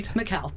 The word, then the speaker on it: Mikkel: Did I get that right? – Mikkel, but close. – Mikkel. – You have to put the accent on the right syllable.